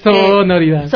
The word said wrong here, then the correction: sororidad: Sororidad. – Sororidad. – A ver, pronuncia diferente. Es que sororidad.